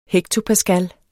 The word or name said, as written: hektopascal